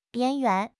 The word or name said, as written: 边缘